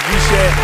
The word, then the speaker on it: RISE: That's RISE, but pronounced as Rishe.